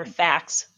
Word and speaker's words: FACS: So you would say that doing the FAQ training, Ekman's FAQ training, if I'm saying it right. – Or FACS. – FACS. Yep. Yeah. With the S on that.